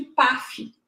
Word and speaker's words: PAAF: a gente chama de PAF. na verdade, são dois As, mas a gente acaba pronunciando como se só tivesse um A.